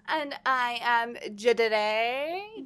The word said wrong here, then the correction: Jade: No, it's pronounced... Gia, just kidding. No, Jade.